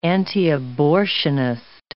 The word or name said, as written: antiabortionist